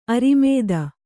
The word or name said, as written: ಅರಿಮೇದ